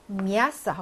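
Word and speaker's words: 미아스 (мясо): 미아스 하고 강세가 있으니까 자기 머리 위에 강세가 찍혀졌으니 당연히 야 하고 소리가 나겠죠?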